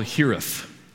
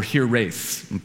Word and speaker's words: hiraeth: It's a Welsh word. It's called hirith, or hirith, pronounced two different ways. H-I-R-A-E-T-H.